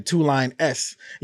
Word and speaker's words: Toulon S: And it's spelled like TULONESS, but it's like a dollar sign, Toulon S.